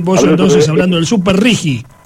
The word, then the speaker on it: RIGI: Pero aparte acá no tiene una U en el medio. claro, sino que es R-I-G-I, Rigi.